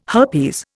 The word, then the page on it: herpes: Indian